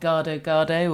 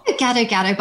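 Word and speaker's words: gado-gado: There's a, is it the, I don't know how to pronounce it right, but Gado Gado or Gado Gado recipe. Oh. – Gado Gado, but I don't actually.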